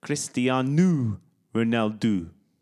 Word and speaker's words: Cristiano Ronaldo: The Portuguese pronunciation is Cristiano Ronaldo. They do an ooh at the end rather than an O.